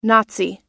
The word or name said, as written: nazi